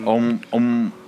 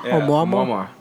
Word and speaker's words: Oumuamua: Oumuamua. – Okay, yeah, he's right. – It's a Hawaiian. – Oumuamua. – Oumuamua.